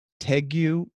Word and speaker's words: tegu: While we're on lizards and iguanas and things like that, I think it's the perfect time to talk about this new tegu lizard. I don't know if I'm pronouncing it right. It's T-E-G-U, tegu, tegu, okay.